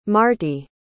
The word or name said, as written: marti